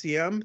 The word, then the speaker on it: Diem: You know, President Diem, spelled D-I-E-M, pronounced Diem.